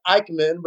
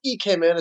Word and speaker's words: Ikemen: It looks like Ikemen, but it's Ikemen.